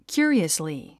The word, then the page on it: curiously: kjú ə riəsli; キュァリァスリィ